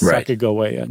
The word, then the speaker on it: Sacagawea: And not once do they spell that third syllable, ga, with a J sound, with a J. It's always a G. Okay. And they think that it was a hard G, so that it's Sacagawea, not Sacagawea.